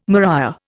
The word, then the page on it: maria: Indian